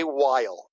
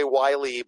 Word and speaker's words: Weil: I'm Jay Weil. It rhymes with smile. A lot of people say Wiley, but that's not correct. It's Weil.